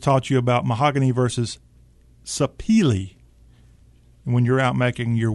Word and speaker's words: Sapele: I'm sorry, Sapele, okay, is how it's pronounced.